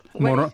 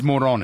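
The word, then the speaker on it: Morón: The town on the Caribbean coast, it's M-O-R-O with an accent over N. How do you pronounce that town? – Moron? – It's Moron, is it? – Yes.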